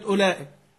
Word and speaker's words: أولئك: كلمة أولئك بعد الهمزة الأولى في إيه؟ واو. مش بنكتب واو ولا بنكتب أولى؟ طب إيه لازمة الواو دي؟ ها؟ هل لها لازمة في النطق؟